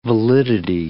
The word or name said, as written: validity